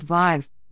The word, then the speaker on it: Vive: A new carbon tracking app called Vive, which rhymes with five, allows you to enter your journeys and calculate the amount of carbon created by your trips.